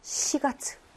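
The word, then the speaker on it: 시가츠 (四月): 시가츠.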